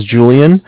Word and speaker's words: Julian: Okay, my name is Julian. It's J-U-L-I-A-N. Mm-hmm. And I'm calling from Bank of Cardiff. It's spelled C-A-R.